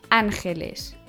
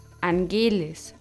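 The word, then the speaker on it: Ángeles: Diese werden auf der ersten Silbe betont von einem Wort mit drei Silben, zum Beispiel Angeles und nicht Angelis oder Angeles. Die Engel.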